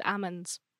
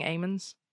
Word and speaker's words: Ammons: I always thought it was Amon's. – Well, it's spelt AMMONS, but when I watched the documentary and stuff, they were saying Amon's, but I think it's because they were American, so I just don't know.